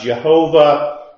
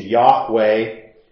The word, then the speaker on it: Yahweh: El Elohim, the word we sometimes pronounce Jehovah, or probably the better pronunciation, Yahweh.